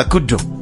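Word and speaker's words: KDDO: Right now though, here's the late AKA and good, or rather Kudo. Kudo.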